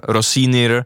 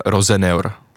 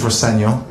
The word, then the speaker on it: Rosenior: Přišel ze Štrasburku a asi byste si řekli, že podle toho, podle té výslovnosti seniora, anglicky senior, Rossinir, ale jeho otec se snad mluvil s někým z Premier League a vysvětlil to, že by se měl vyslovovat jako Liam Rosenior. – And just to clear it up, your surname is pronounced Senior. – My dad says Rosenior, yeah. Which is, it is the way to pronounce it, but as long as it's nice, you say something nice about me, I don't mind.